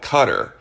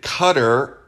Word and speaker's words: Qatar: The reason you hear different pronunciations for this Persian Gulf city is because there's no European letter for the kaf or calf sound in Arabic. So a lot of us Americans call it Qatar. Over there, it seems they pronounce it Qatar, and it seems the locals like to have fun with it and call it gutter.